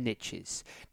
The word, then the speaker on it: niche: The riches in the niches. Now, for my UK listeners, I apologize for using the American term niches. Over here in the UK, for my American listeners, we pronounce niche.